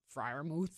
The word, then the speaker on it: Freiermuth: How do you say Pat? Is it Freiermuth? Freiermuth? – Spell it. – Freiermuth. I think he's the tight end for Freiermuth. – Yeah, there you go.